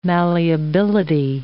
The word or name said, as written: malleability